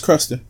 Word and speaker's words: Crusta: Folks, is the Hennessy Cruster. I guess that's how you pronounce it. C-R-U-S-T-A. – That's Cruster.